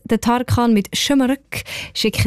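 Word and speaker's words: Şımarık: Also man sagt Schömerück und das heisst übrigens so ein bisschen frech, ungezogen.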